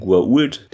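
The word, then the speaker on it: Goa'uld: Dann geht es interessanterweise noch um die Schreibweise von Guault, denn O'Neill meint ja, entschuldigen Sie, Senator, es sollte ein Apostroph äh, vor dem A und vor dem U sein und dann wie wird es nochmal ausgesprochen, so in der Art Richtung Karte und sie dann ja Guaul.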